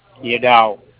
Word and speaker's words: Yadav: A-N-A-N-T-H. – A-N-A-N-T-H. Okay. And then your last name, sir? – Last name is Yadav. – Y-A-D-A-V.